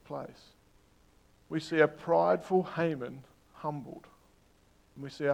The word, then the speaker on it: Haman: He has a name that can be said a couple of different ways. His name is spelt H-A-M-A-N. So we have Haman, the English sort of version of it, and then we have Haman, which is the original old Hebrew way of saying it. I'm going to stick with the English pronunciation for today.